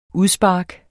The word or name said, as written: udspark